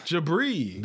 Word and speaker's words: Jabri: Jabri.